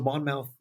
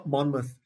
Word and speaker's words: Monmouth: There was a Monmouth, um, uh, Monmouth, uh, excuse me, I mispronounced it,